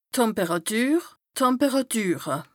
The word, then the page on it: d' Temperàtür, d' Temperàtüre: Bas Rhin; Strasbourg